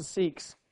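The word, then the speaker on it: Sikh: And let me just come back now to seeks. Oh, Howard, I talked about you at the beginning. I said that you would give mighty resistance to the pronunciation seek.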